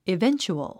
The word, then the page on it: eventual: ivéntʃuəl; イベンチュアル